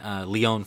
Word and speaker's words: Lyon: No one knew this was really going down, but it's finally being finalized, and I think the first one here is in Lyon, France.